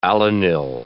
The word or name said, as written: alanyl